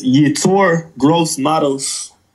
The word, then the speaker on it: Yetur Gross-Matos: It's Yetur Gross-Matos.